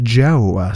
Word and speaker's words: Djo: Basic being basic, that is Joe, spell it D-J-O, but off the stage, you can call him J-O-E.